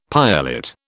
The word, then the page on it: piolet: British English